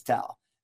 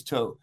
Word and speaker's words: Towe: I know it looks like Toe, but it's Towe. – Yeah, say it right, people. – I was at a conference last week and they kept kept referring to me as Towe.